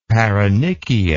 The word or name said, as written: paronychia